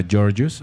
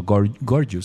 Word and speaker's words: Gorgeous: Y es un estupendo disco del que vamos a escuchar una canción que se llama Gorgeous, o Gorgeous sería la pronunciación, perdón.